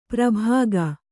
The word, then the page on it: ಪ್ರಭಾಗ: Kannada